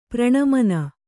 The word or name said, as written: ಪ್ರಣಮನ